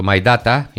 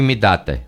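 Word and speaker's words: myDATA: Μη δάτε το My Data ή μη δάτε, μη date. Πώς προφέρεται αυτό? Μη data. – My data. – Δεν είναι my, my είναι με why. – Όχι, είναι μη. Είναι, τέλος πάντων, είναι στα ελληνικά.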